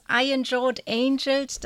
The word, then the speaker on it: Iron Jawed Angels: Und der Kampf, die Reihe Kampf ums Wahlrecht startet in diesem Rahmen dann schon am Donnerstag mit Iron Jawed Angels. Habe ich das richtig gesagt? – Ja, das Iron Jawed Angels,